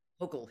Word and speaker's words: Hochul: So that's so interesting because Kathy, you know, Kathy, I'm never sure how to pronounce her name. Is it Hochul? – Hochul, yeah.